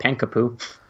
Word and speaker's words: Pankapu: How do you say that name? I always say it wrong. – Which one? – Panakapu? – Oh, I call it Pankapu. – Pain, P-A... – A... – N-K-A-P-U.